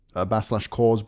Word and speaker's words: Coors: 20 minutes drives away was Burton-upon-Trent, where /Coors Brewery, is that how you say it? – Yeah.